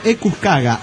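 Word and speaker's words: Equus quagga: Pronúncialo Equus caga. Ah, sí, se caga, perfecto. Caga.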